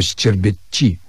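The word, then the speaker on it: şerbetçi: В Турции есть, например, слово «шербетти», произносится оно «чербетти», и означает это разносчик вот этого прохладительного напитка, который в нашем языке принято передавать словом, название которого принято передавать словом «шербет».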